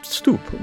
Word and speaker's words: stoep: They called it stoep. Well, it was the same pronunciation, but it was spelled S-T-O-E-P.